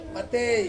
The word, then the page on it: matei: Ansus